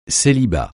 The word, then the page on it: célibat: francais